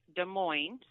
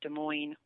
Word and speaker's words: Des Moines: So that says location, and then under that says Des Moines, spelled out D-E-S-M-O-I-N-E-S.